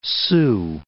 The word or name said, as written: Big Sioux